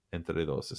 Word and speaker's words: Entre Dos: Entre Dos is how you pronounce it.